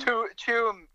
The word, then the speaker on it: Tuam: Because the next time I'm driving from Ennis to Gort on my way to Galway or Tuam, how do you say that? Is it Tuam? T-U-A-M? – Tuam. – Tuam. I never say it right. T-U-A, the Irish spellings are, you know, not your best friend for pronunciation.